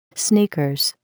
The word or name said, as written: sneakers